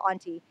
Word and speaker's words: auntie: And I say auntie, not auntie. Some people are bothered, but that is the right way to say it. If you talk to anyone that is from any culture, just about, they say auntie.